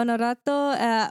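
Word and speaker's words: Honorato: Onoato. Yeah, because the H isn't pronounced in Portuguese, right? – Correct. Yeah. – Yeah, Onorato only had like 100 down, like was it 3,903, yeah, like just shy of 4,000.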